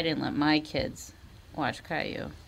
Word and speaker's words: Caillou: I mean, Caillou.